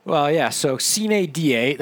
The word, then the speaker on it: sine die: The Latin pronunciation is sine die.